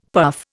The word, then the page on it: puff: Indian